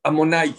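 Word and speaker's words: Adonai: ¿Cómo se pronuncia? Amonai. Se escribe de una manera y se lee de otra manera.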